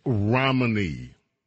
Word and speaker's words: Romney: The author of this piece happens to be Mitt Romney. Rhymes with hominy.